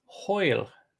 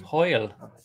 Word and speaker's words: hwyl: Pronounce this Welsh word for me. H-W-Y-L. – Hill. – It's Hoyle. – Hoyle? – Mm. Hoyle. – Damn. Are W's O's? Is that how Welsh works?